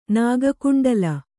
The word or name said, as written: ನಾಗಕುಂಡಲ